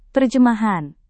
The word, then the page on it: terjemahan: indonesian